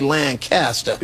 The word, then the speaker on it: Lancaster: Yeah, Lancaster. That's how they say it in Pennsylvania. – Lancaster, Pennsylvania. Here in California, we say Lancaster.